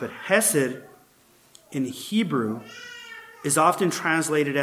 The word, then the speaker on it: hesed: You say it like that, hesed, H-E-S-E-D, right? You can get a little more technical in the way you pronounce it, but I don't want to scratch the back of my throat today by saying it properly, hesed, right?